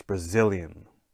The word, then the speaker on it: Brazilian: However, in Brazil and Brazilian, it's still that second syllable. The zil gets the stress, Brazilian.